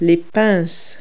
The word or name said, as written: pliers